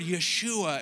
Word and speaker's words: Yeshua: And so when you sing that name, think about that Hebrew word, Yeshua. And when you say it, make sure you pronounce that whoosh, right? Because you need to feel that whoosh.